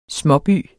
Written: småby

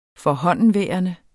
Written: forhåndenværende

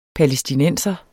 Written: palæstinenser

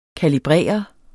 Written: kalibrere

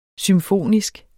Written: symfonisk

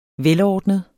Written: velordnet

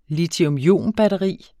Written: litiumionbatteri

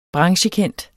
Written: branchekendt

